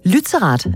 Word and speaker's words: Lützerath: Det er øh, Lützerath. Er det sådan, man udtaler det? – Det er nemlig rigtigt, ja.